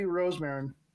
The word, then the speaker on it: Rosemarin: But please welcome to the podcast, Abby Rosemarin.